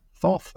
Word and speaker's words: Thoth: Thoth, of course, is a Greek pronunciation of Tuth or Tut. And you'll recognize that sound from Tutankhamun. So in a way, if you were to say Tutankhamun in Greek, you would start with Thothankhamun.